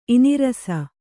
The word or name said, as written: ಇನಿರಸ